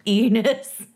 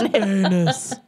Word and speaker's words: Ennis: It's pronounced Enos. – It's Enos. Enos.